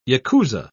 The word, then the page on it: yakuza: British English